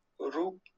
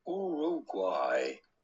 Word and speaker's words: Uruguay: We are looking at how to pronounce with a typical Spanish pronunciation, Uruguay. Uruguay. It'd be fine in English or American to say Uruguay. Uruguay. But in Spanish, Uruguay. Uruguay.